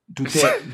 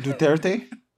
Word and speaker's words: Duterte: Uh, Rodrigo du- du- Duterte. – Duterte.